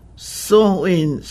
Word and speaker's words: Samhain: In modern times, Samhain, S-A-M-H-A-I-N, a Gaelic word, amen. Samhain, a Gaelic word, pronounced sowing.